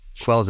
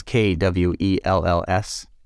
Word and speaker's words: Kwells: Quells is K-W-E-L-L-S. – Yes. – Because I pronounced it K-Wells for almost a year.